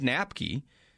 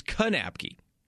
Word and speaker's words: Knapke: I had Jordan on the show yesterday, and even Ed Lamb in the coach's show said Knapke, but his last name is Knapke. You do pronounce the K.